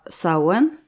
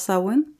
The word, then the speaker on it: Samhain: Det var Sauen om du inte känner igen det så är det för att det stavas Samhain, men det uttalas Samhain.